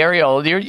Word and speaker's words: areola: I don't know how to pronounce it. – Okay, the areola. You pronounce it perfectly.